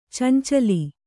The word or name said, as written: ಚೆಂಚಲಿ